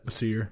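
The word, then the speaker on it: Æsir: How do you say that? – Aesir. – Aesir.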